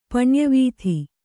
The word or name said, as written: ಪಣ್ಯವೀಧಿ